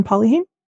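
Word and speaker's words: Polyheme: I think it's Polyheim. Is that the way to pronounce it? Polyheim, exactly.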